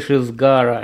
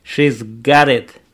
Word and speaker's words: She's got it: S не читается, вернее, не слышится. Got it, буква Т между гласными превращается в нечто очень звонкое и звучит похоже на R.